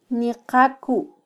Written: niqaku

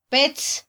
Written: petz

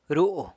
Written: ru

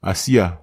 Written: asia

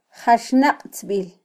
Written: jaxnaqtzbil

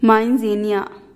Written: main zien nia

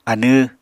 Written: anw

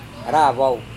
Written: ravau

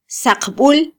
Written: saqbul

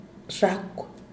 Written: xhakw

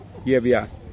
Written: giebia

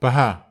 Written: paha

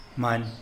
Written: many

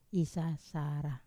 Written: sasara

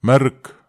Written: merek

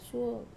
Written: suo

